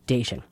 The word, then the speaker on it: Diachun: D-I-A-C-H-U-N. So it looks like Diachun, but it's actually pronounced Dacian.